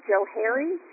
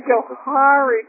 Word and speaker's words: Johari: So if I look at just a concatenation of their names, it should be Joe Harry, but that just sounds really weird. So I prefer the Johari pronunciation.